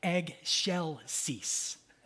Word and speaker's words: Excelsis: And she would, while we were rehearsing, she would be like, Excelsis, all right? So I want everyone with me to just be like, Excelsis, okay?